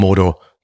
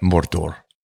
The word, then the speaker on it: Mordor: And this is how they pronounce it. Not Mordor, but Mordor. More doors.